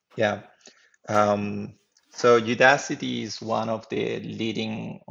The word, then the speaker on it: Udacity: I never know how to pronounce whether it's Ooda City or Udacity.